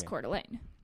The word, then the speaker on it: Coeur d'Alene: The way she described it was how I just said it, which is Coeur d'Alene.